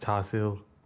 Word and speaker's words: char siu: I love my roast pork and roast duck, you know, which is dinner in Chinese is pronounced char siu, which is literally like one of my favourites.